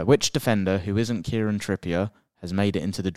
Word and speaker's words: Kieran Trippier: I butchered that name there. Do you know what? I was reading the name. – Kieran Trippier. Kieran Trippier.